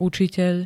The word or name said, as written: ucitel